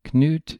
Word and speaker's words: Knut: So, just to put it out there, Knut is spelled K-N-U-T, and in English, where there's a K-N, the K has become silent. So, we tend to spell the king's name C-A-N-U-T-E, and that changes the pronunciation a bit from Knut by adding an extra syllable. Otherwise, in English, it'd be pronounced N-U-T, like nut.